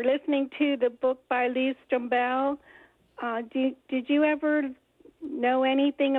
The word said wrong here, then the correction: Strobel: I've always heard his name pronounced Strobel, but then again, maybe.